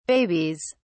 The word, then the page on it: babies: British English